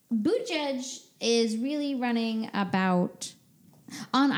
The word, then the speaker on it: Buttigieg: Next, we have Buttigieg. That is the correct pronunciation of Buttigieg. – Buttigieg.